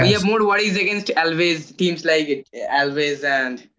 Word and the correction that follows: Alaves: I mean, Alaves, it's not Alves. I mean, I mispronounce that a lot. It's Alaves or Elche or Osasuna.